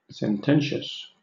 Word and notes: sententious: British English

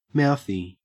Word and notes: mouthy: Australian English

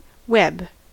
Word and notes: web: American English

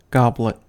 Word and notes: goblet: American English

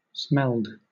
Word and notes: smelled: British English

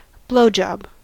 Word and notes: blowjob: American English

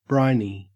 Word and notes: briny: Australian English

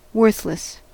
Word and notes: worthless: American English

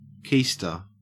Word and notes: keister: Australian English

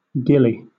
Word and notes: gillie: British English

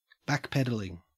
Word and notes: backpedalling: Australian English

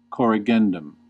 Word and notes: corrigendum: American English

